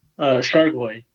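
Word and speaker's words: Chargois: Is it JT Chargoy? – Chargoy, yeah.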